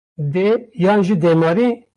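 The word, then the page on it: yan: Kurdish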